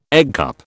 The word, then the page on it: eggcup: British English